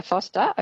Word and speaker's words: Forster: I'm in Foster or Forster. I don't know how they pronounce it here in New South Wales, but Tangari. Yeah, Foster.